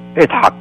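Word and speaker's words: Yitzhak: The correct pronunciation, by the way, is Itzhak.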